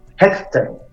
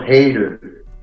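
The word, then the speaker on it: Hejter: Pero este Misiaek, es como se llama, porque la película en, polaco se pronuncia Hector, no Heider, como está diseñado el nombre y como está diseñada además la idea de promover.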